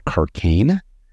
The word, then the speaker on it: Cracagne: The name of the boat is called Carcane. C-R-A-C-A-G-N-E, which I think actually, as it turns out, rhymes with migraine because this boat's been kind of a headache for us.